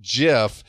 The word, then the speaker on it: GIF: Now, to be clear, you hate the word gif and not that yes. We're talking about including LGBT characters in films. – It's GIF. It's graphic interchange format. It's a hard G.